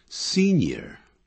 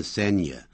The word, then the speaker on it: senior: 常用这样的这类的形容词啊，一共有四个，像 superior to 比什么更好更优越， inferior to 比什么差劲 inferior， superior 这样念啊，不要念 superior inferior，不是这样念， superior superior inferior, inferior, senior, 不要念成 senior, senior, 比什么年长的都要加 to, 或者比什么地位高的叫 senior, junior, 比什么年纪轻的或比什么地位低的我们都要用 to 这样用法。